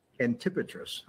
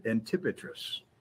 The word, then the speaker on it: Antipatris: Now, the destination was Antipatris. Is that the right way to say it, Doc? – Antipatris. – Okay. Antipatris. It's about 42 miles northwest of Jerusalem.